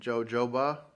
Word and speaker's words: jojoba: Organic cold pressed argan, apricot, kernel, and jojoba. Is that how you say it? – Yeah.